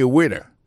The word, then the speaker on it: waiter: I am a waiter. Ça veut dire, moi, son garçon m'a servi dans le restaurant. Alors, moi, je prononce le waiter. Waiter, ça veut dire accent tombé sous le premier syllabe là. Waiter. W-A-I-T-E-R.